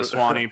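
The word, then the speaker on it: Suwannee: I don't even know how you pronounce it. Is it a Swanee bass? – Yeah, the Swanee bass.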